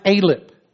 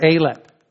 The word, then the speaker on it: Aleph: I'm going to start with Psalm 119, verse 1, and it's pronounced Aleph or Aleph.